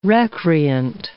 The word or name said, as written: recreant